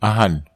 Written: ahan

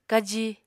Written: kaji

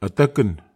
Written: ataken

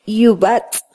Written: yubatz

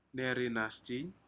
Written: nerinasjin

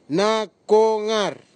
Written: nakogar

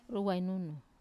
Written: ruwainunu

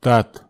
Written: tat

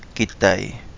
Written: gidai

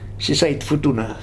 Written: Sisait

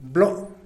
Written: blo

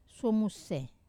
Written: suomuse

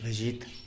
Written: bezhit

